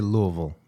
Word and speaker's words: Louisville: And they say Louisville, not Louisville.